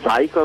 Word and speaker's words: Zaico: allora, eh, il ehm, Zaico è una scoppiatura, diciamo, della parola psycho, cioè ah. psicopatico, diciamo. E ehm, non saprei dire perché l'abbiamo storpiato, forse per farlo suonare come un nome proprio, credo.